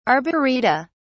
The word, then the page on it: arboreta: British English